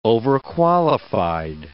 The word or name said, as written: overqualified